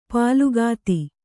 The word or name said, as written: ಪುಲಕಿತ